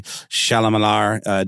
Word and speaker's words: Chalamet: And I think Timothy Chalamet did a fine job. – Is that the correct pronunciation? – Yeah.